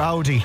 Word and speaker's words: Audi: It's your Audi. Or Audi, depending on your pronunciation.